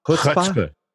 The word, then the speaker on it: chutzpah: I mean, they're demonstrating that, I mean, first of all, they got a little, how's it pronounced? Chutzpah? – Chutzpah. I think the chutzpah.